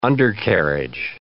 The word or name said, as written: undercarriage